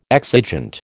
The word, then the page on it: exigent: American English